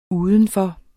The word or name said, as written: udenfor